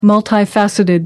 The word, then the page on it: multifaceted: American English